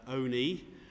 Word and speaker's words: Olney: I was at Olney, the locals pronounce it Olney, it's spelt Olney for the rest of us, north of Milton Keynes, on Tuesday for the shriving service.